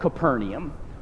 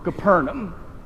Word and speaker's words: Capernaum: it was a town called Capernaum, or Capernaum.